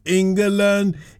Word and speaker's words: England: England. It's three syllables, just like Wembley.